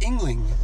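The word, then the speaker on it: Yuengling: It's pronounced Yingling. – Yingling. – But I knew it was an American beer, and I went to the bar, and I thought it was like, I'll have a Yingling. – Oh, wow. – That's how it's spelled, Y-E-U-N-G, right? – Yeah.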